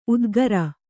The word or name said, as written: ಉದ್ಗರ